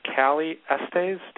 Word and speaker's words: Cali Estes: We have been talking with Callie Estes.